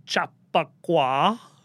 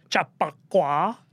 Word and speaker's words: Chappaqua: On top of all that, she has a private server in Chappaqua. I don't know if I'm pronouncing that word right. Sounded right. – Chappaqua.